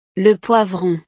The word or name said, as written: le poivron